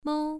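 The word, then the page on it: mou: tone 1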